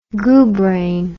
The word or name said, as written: goobrain